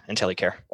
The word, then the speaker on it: Intelycare: So your company is Intelycare, right? Am I saying it right? – That's correct. Intelycare. – Okay, I was calling it Intelycare, but I see it's called Intelycare.